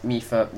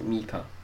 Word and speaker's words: Mipha: Mipha. Nah, okay. You're making me pronounce it Mipha.